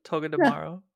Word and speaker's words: Togedemaru: So Togedemaru. Did I say that right? – Togedemaru. – Okay.